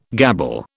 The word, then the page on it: gabble: British English